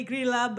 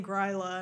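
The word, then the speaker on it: Grýla: It should be Grila, but they keep saying Gryla, and I'm like, isn't she mad?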